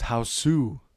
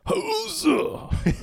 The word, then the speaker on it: Hausu: Yours is, I think, pronounced Houseu. – Originally known as Houseu.